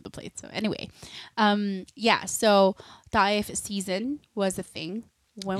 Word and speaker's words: Taif: Taif. It's so hard to mix the language of because there's an English pronunciation and there's. – The stress is still in the first syllable. It's just the Filipino way.